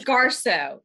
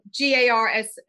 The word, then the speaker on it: Garsaud: It's Garsaud. Garsaud. It's like Garsaud. Garsaud. It's how it's pronounced.